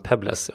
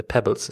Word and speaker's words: Pebbles: Jag har förstått att hon uttalar sitt namn Pebbles och inte Pebbles som jag har trott.